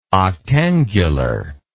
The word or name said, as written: octangular